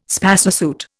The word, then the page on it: spacesuit: Indian